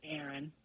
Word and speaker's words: Erin: My name's Erin. – Erin. – Spell that. – E-R-I-N.